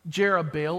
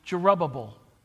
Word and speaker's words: Jerubbaal: Jerubbaal, or old pronunciation, would be Jerubbabel.